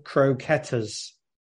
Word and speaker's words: croquetas: ¿Cocoretas? ¿Cómo se dicen? Nah, bowl. – Croquetas. – Croquetas.